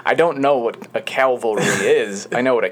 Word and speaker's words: Calvary: Is it Calvary? – Calvary, not Cavalry.